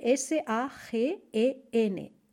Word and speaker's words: sagen: Se escribe tal cual, ¿eh? s a g e n s a g e n S-A-G-E-N, S-A-G-E-N, sagen.